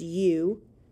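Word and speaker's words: eu: The first root word is spelled E-U and it's pronounced you, which means good or well.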